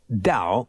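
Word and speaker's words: Tao: That's T-A-O, but pronounced Dow, like D-O-W.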